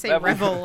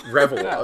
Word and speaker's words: Reville: And then reading that out loud, I was like, that just doesn't sound it's like Neville, right? Like Neville. – Revel like Neville. – Revel. Yeah, okay. – Because Neville is spelled the same way with the N, so.